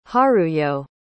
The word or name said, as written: haruyo